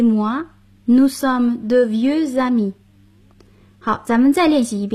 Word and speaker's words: amis: Jean et moi, nous sommes de vieux amis. – 我和 Jean 是老朋友了。复数形式的朋友就是在阿密后加上 s，但并不发音。